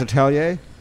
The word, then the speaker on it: atelier: It's pronounced Atelier.